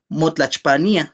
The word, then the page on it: motlachpania: Náhuatl